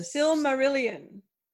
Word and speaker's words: Silmarillion: I know I've said it here a few times, but Silmarion. Silmarion. – Silmarion? – Silmarion. – I'm going to look up how it's spelled. – People know what we're talking about. If they're a Belgariad fan, they're a Lord of the Rings fan, and they'll know the Silmarillion. Silmarillion. – Okay. – S-I-L-M-A-R-I-L-L-I-O-N.